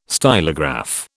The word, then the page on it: stylograph: British English